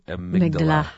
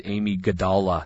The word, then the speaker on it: amygdala: Actually, if you said it like it's spelled, you would come up with Amy Gadala.